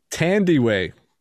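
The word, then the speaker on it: Thandiwe: And I actually looked up how to pronounce her name. Tandy Way. She changed her name to Tandy Way Newton.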